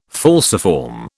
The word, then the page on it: falciform: British English